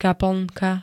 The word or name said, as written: kaplnka